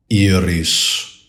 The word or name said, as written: IRIS